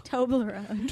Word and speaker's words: Toblerone: How do you say it again? Turbolone chocolate stuff. – Toblerone.